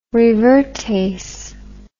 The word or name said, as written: revertase